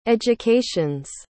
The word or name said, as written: education's